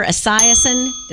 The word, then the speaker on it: Esiason: Boomer Esiason, did I say that right? – You did.